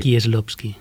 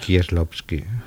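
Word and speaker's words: Kieślowski: No que bueno, está ligado por siempre a la trayectoria de otro polaco, por supuesto, de Krzysztof Kielowski. – Kieslowski. Pronúncialo bien, Cervera. Kieslowski. Muy bien, me gusta. Con acento de Varsovia.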